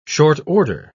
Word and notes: short-order: British English

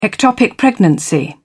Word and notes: ectopic pregnancy: British English

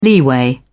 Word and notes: leeway: British English

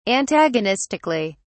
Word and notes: antagonistically: British English

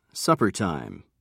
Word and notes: suppertime: British English

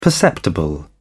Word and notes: perceptible: British English

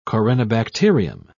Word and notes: corynebacterium: British English

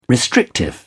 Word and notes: restrictive: British English

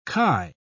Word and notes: khi: British English

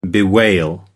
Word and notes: bewail: American English

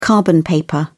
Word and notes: carbon paper: British English

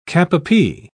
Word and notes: cap-a-pie: British English

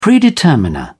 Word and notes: predeterminer: British English

